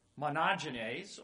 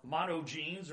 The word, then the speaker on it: monogenes: It's a Greek word, monogenes, or monogenes, or monogenes, depending on how you want to pronounce it.